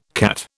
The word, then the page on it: CAT: British English